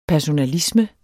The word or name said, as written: personalisme